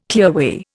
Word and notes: clearway: Indian

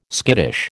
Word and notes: skittish: American English

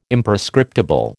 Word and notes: Imprescriptible: American English

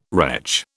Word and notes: wretch: British English

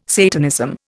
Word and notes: Satanism: Indian

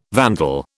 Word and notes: vandal: British English

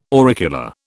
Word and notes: auricular: British English

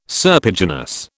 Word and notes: serpiginous: British English

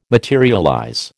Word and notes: materialize: American English

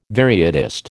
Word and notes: varietist: American English

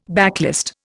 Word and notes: backlist: Indian

